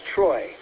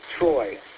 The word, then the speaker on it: Troy: The name here is Troy. Spelled phonetically Tango, Romeo, Oscar, Yankee. Troy.